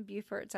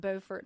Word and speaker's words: Beaufort: So, you're from Beaufort, South Carolina. – Born and reared. – Not Beaufort, North Carolina. – Not Beaufort, but Beaufort.